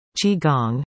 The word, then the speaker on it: Qi Gong: Qi Gong, pronounced Qi Gong, is often mistaken for Tai Chi because both are Far Eastern arts that place an emphasis on movement and breathing.